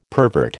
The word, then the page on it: pervert: American English